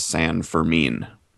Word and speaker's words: San Fermin: But on the band's website, on the About page, it does say the name is pronounced San Fermin.